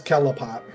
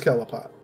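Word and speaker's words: Qliphoth: The Tree of Cliffoth. – That's how you pronounced it? – I just want to say shout out to It's Kellapot. – What the fuck? – It is Kellapot.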